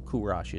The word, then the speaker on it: Kurash: But when the Persians were founded under a great leader, one of the great men of history, Kurash, Cyrus is how we know him now. Kurash is a closer pronunciation. I don't pretend to be perfect, but a closer pronunciation of the way the Persians would have said it.